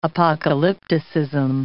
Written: apocalypticism

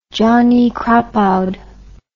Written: johnny crapaud

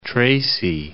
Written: Tracy

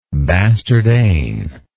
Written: bastard eigne

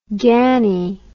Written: ganny

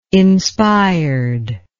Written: inspired

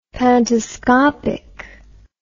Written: pantoscopic

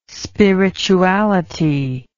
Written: spirituality

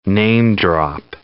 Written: name-drop

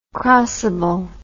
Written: crossable

